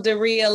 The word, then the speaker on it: Durriyyah: I am Durriyyah like Maria. It's the easiest way for people to pronounce my name, right? But I am Durriyyah Anderson.